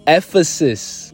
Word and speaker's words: Ephesus: And the pronunciation of the word E-P-H-E-S-U-S is Ephesus.